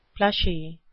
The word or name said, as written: plushy